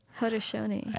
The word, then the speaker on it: Haudenosaunee: They said I have to learn how to pronounce my words correctly because I used to always pronounce it Haudenosaunee, people of the Longhouse. I guess it's supposed to be pronounced Haudenosaunee. – Haudenosaunee.